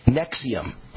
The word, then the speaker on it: NXIVM: NXIVM is pronounced Nexium. Okay, Nexium.